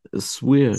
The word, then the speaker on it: Suir: S-U-I-R? Swear.